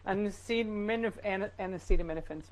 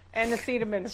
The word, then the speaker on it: Acetaminophen: How do you say it? Acetaminophen, sir. Acetaminophen.